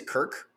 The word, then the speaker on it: Cirk: Also, I'll say before you say it, his name is Kirk. Kirk with a C. How does that make sense? It's written down Cirk. – It's spelled Cirk. Kirk C-I-R-K, with a C.